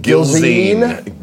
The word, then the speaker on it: Gilzean: Gilzean.